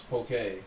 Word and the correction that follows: poke: Poke bowl. – Yeah, it's pokey.